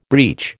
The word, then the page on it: breach: American English